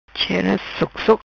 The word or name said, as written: Chera Sok Sok